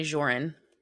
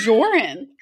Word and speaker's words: Joran: So Jordan, okay. Is that pronounced Jordan or Joran? – No, I think it's definitely Joran. – Oh, it's Joran. – Yeah. I think the emphasis was on the J.